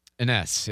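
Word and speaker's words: Enes: Okay, so back to Anas Kanter, or Anas, I always want to say it wrong. I think it's Ines is how you say it.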